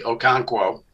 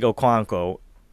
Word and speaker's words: Okonkwo: Just call him Chig Okonkwo. – Yeah, Chig Okonkwo.